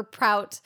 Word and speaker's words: Prout: And Kevin Spacey goes, or Prout, rhymes with oat.